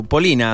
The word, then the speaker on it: Polina: Estoy, estoy viendo acá su perfil, eh, Polina Marinova, lo, Polina, así como, como Polino, pero con A, e igual, se escribe igual.